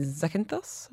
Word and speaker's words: Zakynthos: splitting our time between Kefalonia and Zakynthos. Am I saying that right? – Yes, you are.